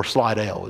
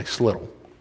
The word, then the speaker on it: Slidell: Two lanes go left, and if you stick on that, you're going to get the Slittle, or Slidell as it's more commonly pronounced. But us Southeast Louisiana people say Slittle.